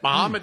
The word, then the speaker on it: Mahomet: I'm doing Vincennes and Columbus, Indiana, and Mahomet. Finally learned how to say it. Mahomet, Illinois.